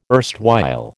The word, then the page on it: erstwhile: American English